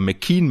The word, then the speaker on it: McKeon: Nee, Kaylee McKeon, Entschuldigung, Kaylee McKeon, beide sehr ähnlich geschrieben, Emma McKean mit K-E-O-N und Kaylee McKeon, K-E-O-W-N. Auch hier mal schön die wirklich native australische Aussprache zu hören, dann kriegt man auch mal mit, dass dass die Emma, die 100 Delfin-Schwimmerin, eher McKean mit Doppel-I quasi gesprochen wird und McKeon noch so ein kleines O mit drin hat.